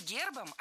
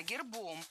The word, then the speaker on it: гербом: Не гербом, а гербом. В этом слове при склонении ударение переносится на окончание.